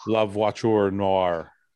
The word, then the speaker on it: La Voiture Noire: La Voiture Noire.